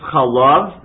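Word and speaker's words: chalav (חָלָב): So this Hebrew word can be pronounced chalav, milk, or chalev, fat.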